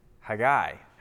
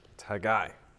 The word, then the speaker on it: Haggai: We are going to be in Haggai, as it's traditionally pronounced, but it's Haggai.